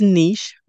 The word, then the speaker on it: niche: So the first one is niche, which is how you'll hear me say it most of the time.